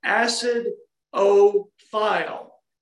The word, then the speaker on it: acidophilus: Well, uh, I want to pronounce that. We're Alex and I are in Middle Tennessee, so we're going to reveal ourselves right now. I want to pronounce that acid o file.